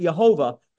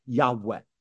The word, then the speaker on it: Yahweh: Some people would pronounce it Yehovah, but most Semitic scholars believe it's pronounced a breathy Yahweh.